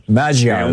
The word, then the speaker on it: Magyar: Yeah, Magyar is how it's pronounced.